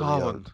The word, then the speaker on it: Samhain: I know we say it wrong. I get it, guys. I don't know how to say it. Whatever. Samhain. But Solon. Anyway. Samhain. Whatever. Solon.